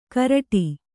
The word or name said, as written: ಕರಡಿ